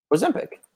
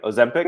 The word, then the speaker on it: Ozempic: How do you say it? – Ozempic. – Is that right? Ozempic? – Yeah, okay. You got it.